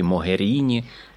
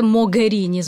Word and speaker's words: Могеріні: Могеріні чи Могеріні? – Ну, італійською це Могеріні, звичайно ж, тому що у них немає букви Г, але я думаю, що можна і Могеріні. – Добре, значить, ми будемо по-італійськи. Федеріка Могеріні,